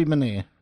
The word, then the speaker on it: Bonilla: Bobby Bonilla.